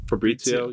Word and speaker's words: Fabrizio: How do you pronounce his name? – Yeah, Fabri- is it Fabrizio? – Fabrizio. You got yeah, Fabrizio and Taylor Twellman.